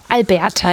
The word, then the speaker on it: Alberta: Alberta in Kanada.